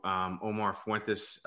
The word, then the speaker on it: Omar Fuentes: So Omar Fuentes, CEO of Acceleq.